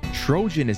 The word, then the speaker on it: Trojan: And an interesting fact I was told by my correspondent, Zach, from the Czech Republic, told me Trojan is actually pronounced Trojan in Eastern Europe.